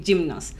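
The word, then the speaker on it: Gymnos (γυμνός): ¿Sí se dice, sí se pronuncia así? Sí, Gymnos. Gymnos.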